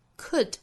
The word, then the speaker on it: could: Could 若读成 could。 Could 元音从变成刷 sound 单词内部的字母 l 是不发音的。